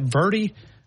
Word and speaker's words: verde: How do you say that? Verde? Is it Salsa Verde? Is that the way you say it? – Verde. – Verde. – Verde. – Verde. Verde burger.